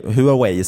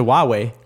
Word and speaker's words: Huawei: Precis, det är som Huawei, Huawei som ägs av sina anställda säger man ju också. Nu har jag ju lärt mig att det heter Huawei.